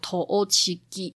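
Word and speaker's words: 栃木: 加起来总共是十个木头，所以在日文里面就汉字叫做十千木，那日文念作 Toochigi，据传就是这样子来的。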